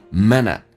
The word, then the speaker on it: minute: Only 跟 a 可以加个零，元音跟元音之间零不是加我就是加也，那这个加个也叫 only it, only it minute，短元音，这个发音 minute，同时 t 要去掉，这是略音，但是气流要阻塞一下，因为 t 前面是一个元音叫 minute， minute to get a crush on someone， get 跟 a 有一个连音叫 get， get crush 跟 on 有一个连音叫 crush on， crush on， crush on someone，这句话我们慢速版本来读一遍，变成 it takes only a minute to get a crush on someone.